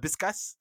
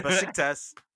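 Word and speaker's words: Beşiktaş: Back in the days, I would be like, Biskas? But Besiktas, I know how to say it now.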